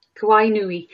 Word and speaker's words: Kawainui: Kawainui.